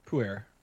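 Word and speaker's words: Pu'er: How do you say that again? – Puer. – Puer teas.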